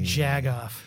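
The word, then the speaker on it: jagoff: But said with a hard G on the jag. Jag off.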